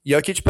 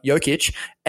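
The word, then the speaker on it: Jokić: Like, you can get a third string, fourth string guy like Monte Morris to help Jokic, and like, you're there. You get the win. – He's got a soft J, like yogging.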